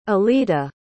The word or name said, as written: Oleta